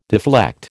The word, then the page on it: deflect: American English